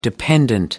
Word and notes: dependant: American English

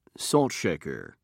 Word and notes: saltshaker: British English

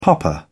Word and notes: popper: British English

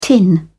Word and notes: tin: British English

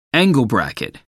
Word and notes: angle bracket: American English

